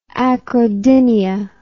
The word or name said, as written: acrodynia